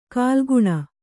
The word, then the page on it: ಕಾಲುಗುಣ: kāluguṇa